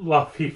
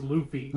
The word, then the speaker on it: Luffy: And it is not Luffy, for the love of God, it's Luffy. – Luffy, okay, sorry.